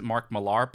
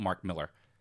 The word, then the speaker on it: Millar: Mark Miller, or I think some people pronounce it Mark Millar, but I double-checked some BBC interview and they call it Mark Miller, is a comic book writer, author.